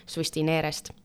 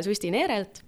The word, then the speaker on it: Sustinere: hästi, ma küsikski siis esimese küsimuse Susti Neerelt, et äh, millega Susti Neere tegeleb oh,? ma küsin vahele, kas ma hääldasin õigesti? – jaa, täitsa õigesti. – Susti Neere, väga hea äh,